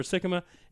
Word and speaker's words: Sikkema: For those of you who don't know who he's here, Trevor Sikkema. Is that the correct pronunciation? – Sikkema, yep. – Look at that, like Hickama.